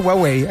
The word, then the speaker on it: Huawei: De partida yo estoy pronunciando a lo Alexis Sánchez Huawei, así salía con, lo decía Alexis, así suena, así que nada más.